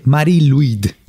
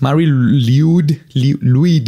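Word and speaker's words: Mari Lwyd: Del otro lado del charco francés, los galeses tienen su propio espectro navideño en la forma de Marie-Louis, que se escribe L-W-Y-D. Ya ves que los galeses, su idioma está bien pinche raro. – No tiene vocales esa palabra, güey. – No tiene vocales. Marie-Louis.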